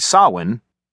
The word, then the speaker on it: Samhain: To retaliate, my dad started calling me Sam, since he said Sawin, which is how S-A-M-H-A-I-N is pronounced, sounded funny.